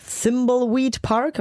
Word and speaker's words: Thimbleweed Park: Das Spiel, um das es aber jetzt geht, heißt Thimbleweed Park.